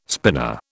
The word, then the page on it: spinner: British English